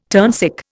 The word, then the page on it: turnsick: Indian